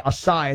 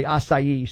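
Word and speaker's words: açaí: People say acai. It's actually acai.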